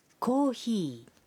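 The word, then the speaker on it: কোহি (コーヒー): coffee শব্দটির জাপানি উচ্চারণ কোহি কোহি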